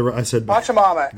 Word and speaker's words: Pachamama: Pachamama, I think. – Right. Yes, you're saying it right. I said Pachamama. – Exactly. Yes, sir. – It's absolutely Pachamama.